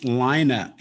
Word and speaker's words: Lyna: Spells it L-Y-N-A, pronounced Lina, and don't fuck it up, you piece of shit.